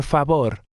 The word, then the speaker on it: favor: Por favor. – Notice that in Spanish, the letter V sounds like our letter B.